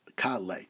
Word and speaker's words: kalei: When you say kalei, kol tzaru mastimi oleinu hakaf bepatach v'alamid b'tzeri. That's the way you pronounce it, kalei.